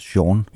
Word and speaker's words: Chan: Ja, jeg har altid troet, det hed Sean Marshall, men har så fundet ud af, at jeg forberedte mig til det, at det faktisk udtales Sean.